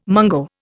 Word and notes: mungo: Indian

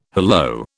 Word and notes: hallo: British English

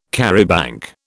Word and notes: charabanc: British English

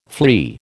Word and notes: flea: American English

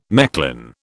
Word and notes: Mechlin: British English

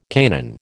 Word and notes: Canaan: American English

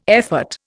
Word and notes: effort: Indian

